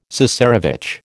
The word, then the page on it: cesarevitch: American English